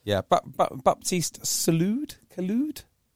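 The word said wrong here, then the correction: Couilloud: however you say his name, when he came on, he looked... – Cuiu. – That's an incredible pronunciation. I would never have got there. – Cuiu.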